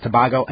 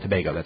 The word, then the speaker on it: Tobago: By the way, yes, I know a lot of people wrote in to say I keep pronouncing it Tobago. It's hard because when I'm reading it, I think Tobago because, I don't know, it's just the way it looks. But it's definitely Trinidad and Tobago.